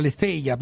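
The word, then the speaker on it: aletheia (ἀλήθεια): C'è una parola greca molto bella, adesso io la pronuncerò male, ma si dice aletheia. TH dovrebbe essere aletheia, bisognerebbe dirlo così.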